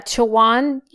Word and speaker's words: Chowan: And, Law- and we had an argument about how to pronounce this, but I think it sounds like you're right. – Yeah, Chowan University. It looks like in Chowan.